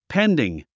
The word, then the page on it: pending: péndiŋ; ペンディン